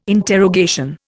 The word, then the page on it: interrogation: Indian